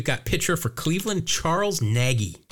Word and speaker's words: Nagy: There was a woman in the league whose last name was Nagy, but she pronounced it Naj. And it just screwed me up because I've known Charles Nagy.